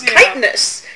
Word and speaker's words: chitinous: And I'm like, no, it's chitinous, you know?